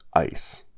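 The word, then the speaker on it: eis (εἰς): It is the Greek word eis. It means into, and that is spelled E-I-S.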